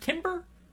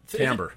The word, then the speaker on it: timbre: Is it timber? – Timbre. – Is it timbre? – I think I said timbre, didn't I? – You did say timbre. – Yeah. – Why is it pronounced timbre? If it's spelled timber. – Well, it's not really. It's got an R-E at the end, so it's sort of French.